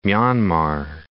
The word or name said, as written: Myanmar